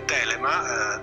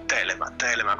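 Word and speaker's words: Thelema: E che devo dire, è arrivato un procione viaggiatore, l'ha mandato il nostro principe Calù, che ci dice innanzitutto si dice Telema, dicevamo prima della setta fondata a Cefalù eccetera, Telema con l'accento sulla prima E.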